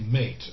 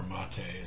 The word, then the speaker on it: MATE: And while XFCE might be one of the friendlier ones for resources, it's not as accessible as the Mate, or Mate is actually pronounced.